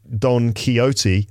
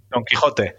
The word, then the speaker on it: Quixote: It comes from the character, as we say in British English, Don Quixote. How do you say it Don Quixote. Yeah, we will use the strong.